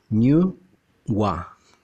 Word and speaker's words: Nü Wa: Entonces, el primer ser humano se dice que fue niu Wa, se escribe N y la U con diéresis y separado WA, New Wa.